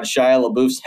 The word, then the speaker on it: Shia LaBeouf: Shia LaBeouf? I can never pronounce it. – Shia LaBeouf, yeah.